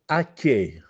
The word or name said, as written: Acchiè